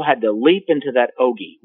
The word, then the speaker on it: ogee: In the palm of the hand, they saw a slit in the sky, which they called an ogee. That is spelled O G E E, an ogee.